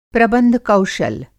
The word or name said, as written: प्रबंध कौशल